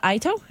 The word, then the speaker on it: Itoh: The Ito, am I pronouncing that right? – Yep.